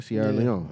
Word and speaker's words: Sierra Leone: I want to go to Libya or Sierra Leone. Sorry if I keep saying it wrong. – That's right, Sierra Leone.